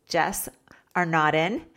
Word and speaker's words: Jess Arnaudin: Today I have my guest, Jess Arnaudin.